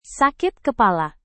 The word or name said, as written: sakit kepala